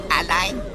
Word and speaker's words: allein: Alien, Korrektur, allein.